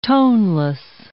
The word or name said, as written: toneless